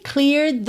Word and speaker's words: cleared: Atenção à pronúncia, cleared the plate. Você nem percebe que está no passado, né? Que é cleared. Porque nessa terminação é de, o E não é pronunciado.